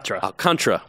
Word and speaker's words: Alcantara: Alcantara.